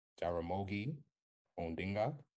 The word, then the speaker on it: Jaramogi Odinga: In 1966, there was an opposition group, Kenya People's Union, that formed, and it was led by the former vice president, probably going to butcher his name right here, Jaramogi Odinga. – You got it.